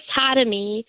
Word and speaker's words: Khatami: He and one of my best friends, Chris Hatami, which is spelled K-H-A-T-A-M-I, they started a podcast a year ago called Spec Script.